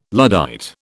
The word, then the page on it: Luddite: British English